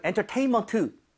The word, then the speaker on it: entertainment: 자, entertainment도 여기 강세를 줘서 여러분 entertainment 이게 아니라 네.